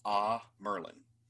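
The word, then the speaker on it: Amyrlin: And I will say Amerilin, but I think the proper pronunciation is Ah Merlin.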